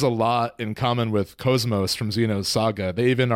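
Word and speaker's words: KOS-MOS: In Xeno's Saga, at least initially, Cosmos is how they say it.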